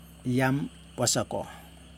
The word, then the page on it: jam wasako: Yokoim